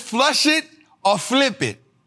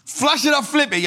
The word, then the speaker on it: Flush It or Flip It: This is Flesh It or Flip It. I didn't say it right? What I said? This is Flush It or Flip It.